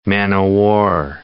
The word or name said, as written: man-o'-war